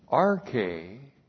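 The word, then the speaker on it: R-K (ἀρχή): A-R-C-H, long E, R-K. All right, now this is very important here. So one, R-K is the Greek word for beginning or origin.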